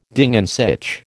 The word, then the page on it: Ding an sich: American English